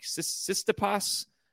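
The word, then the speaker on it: Tsitsipas: I always miss his name wrong, but it's like Sistipas. He's Greek.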